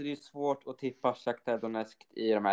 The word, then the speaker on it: Shakhtar Donetsk: Shakhtar Donetsk. Shakhtar Donetsk och Celtic.